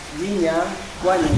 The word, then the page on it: vinya wanya: Yawa